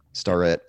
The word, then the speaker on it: Starrett: Starrett, yeah.